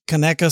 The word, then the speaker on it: Conecuh: So for those of you who are listeners who don't know, it's spelt like Konecuh, Conecuh, Kaneka sausage.